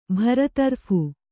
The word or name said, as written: ಬರತರಫ್